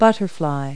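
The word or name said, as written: butterfly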